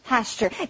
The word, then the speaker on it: Pasteur: That's P-A-S-T-U-R-E. And his name sounds a lot like that, except the emphasis is on the second syllable, so it's Pasteur.